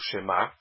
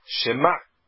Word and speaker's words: Shema: Isso significa que a sílaba tônica é o Ma. É uma palavra que é oxítona. Ela é milerá. Shema Israel. E não Shema. É Shema.